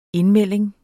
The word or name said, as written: indmelding